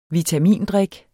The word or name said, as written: vitamindrik